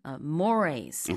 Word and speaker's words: mores: 단어라서 순간적으로 mores 이렇게 발음을 했었는데 사전적으로 이제 발음 기호를 찾아보시면은 Morays Okay. – 이렇게 나와 있긴 합니다.